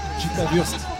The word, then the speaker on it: Conchita Wurst: Conchita Wurst.